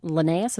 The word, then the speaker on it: Linnaeus: And at that time, botany had just gone through a major change where Linnaeus, is that how you say his name? – Yes, Carl Linnaeus.